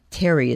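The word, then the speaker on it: Thierry: In 1798, Terry is how it's pronounced.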